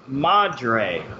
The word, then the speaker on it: Madre: Number 12 is this year's, am I saying this right, Madre, okay. – Yes.